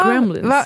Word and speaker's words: Kremlin: När han häromdagen var på besök på självaste Kremlin. Nu uttalade jag det som Kremlins. – Ja. – På självaste Kremlin.